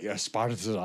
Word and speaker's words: Esparza: I got to say it right. Esparza. I told him I rolled my tongue.